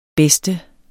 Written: bedste

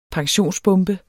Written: pensionsbombe

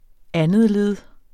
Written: andetled

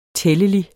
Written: tællelig

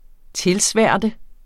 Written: tilsværte

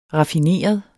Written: raffineret

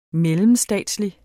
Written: mellemstatslig

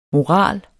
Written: morale

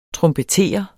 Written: trompeter

